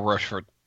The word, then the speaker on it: Rochefort: Is that how it's said? Rochefort? – I think it's more Rochefort, or... Rochefort, I think, is the way it is. At least that's the French pronunciation, and I believe it's the French part of Belgium.